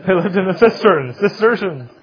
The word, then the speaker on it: Cistercian: Also, the Cistercians is how you say that one.